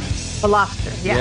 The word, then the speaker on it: Veloster: Check out the new Hyundai Veloster. Is that how they say it? – Veloster, yeah. – Yeah, V-E-L-O-S-T-E-R, Veloster. Good little car.